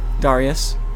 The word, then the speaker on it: Darius: Darius. That's how you pronounce it.